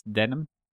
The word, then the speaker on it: Denholm: It's Denim. – Denim? Okay. Yeah. I think over here in North America, we've been pronouncing it wrong for a long time.